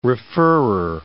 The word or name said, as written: referrer